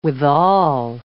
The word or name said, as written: withal